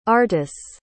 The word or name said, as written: artus